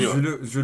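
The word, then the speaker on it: Süle: Sule, le il, est, il, est, forfait aussi.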